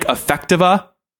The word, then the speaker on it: Affectiva: Is it pronounced, Affectiva? – Affectiva, and many, many others.